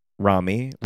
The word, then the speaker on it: Romy: What would it be like if Kylie has sounded like the Midair by, it's not Romy, though. How do you say it? Rami. – Rami like Tommy.